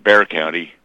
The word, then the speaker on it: Bexar: Well, they pronounce it Bear County.